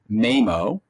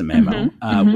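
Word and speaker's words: Maemo: So this ran MAMO, or MIMO, I think it was MAMO. I've always pronounced it MAMO,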